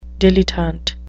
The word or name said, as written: dilettante